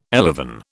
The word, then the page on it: elevon: British English